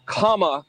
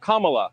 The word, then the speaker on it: Kamala: Her name is pronounced comma, like the punctuation mark. Look, Kamala. Okay. Seriously, I've heard every sort of bastardization. – So what? – That's how it is, Kamala.